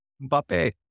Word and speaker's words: Mbappe: and for the final to be Messi versus Mbappe. – I did. Listen, how do you pronounce that? I listened to the video. – Mbappe.